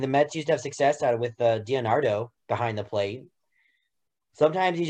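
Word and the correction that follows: d'Arnaud: just gotta sign a guy and hold on. Pronounce that again. – DiNardo. – Darno. – Oh, it is Darno. I say it wrong.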